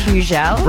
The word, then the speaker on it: Hugel: It's Hugel.